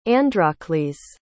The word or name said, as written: Androcles